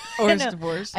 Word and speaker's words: hors d'oeuvres: Hors d'oeuvres.